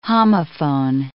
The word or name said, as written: homophone